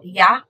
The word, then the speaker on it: ja: J sounds are pronounced like an English Y, for example, ja and januar.